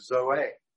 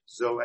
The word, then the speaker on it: Zoe: zoe, which you will recognize as Z-O-E, okay? Z-O-E in the name Zoe means life, okay? But Zoe, the Greek pronunciation, is life, true life, eternal life, okay?